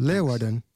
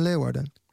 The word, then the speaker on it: Leeuwarden: Leeuwarden. You're yes. – playing in Leeuwarden.